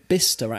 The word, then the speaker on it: Bicester: Oh, it's called Bicester, actually.